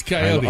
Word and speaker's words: Kaiyote: I mean, it's the Coyote is spelled K-A-I-Y-O-T-E. – I know it from looking at the list yesterday. – Hiatus Coyote. – I'm unfamiliar with Hiatus Coyote.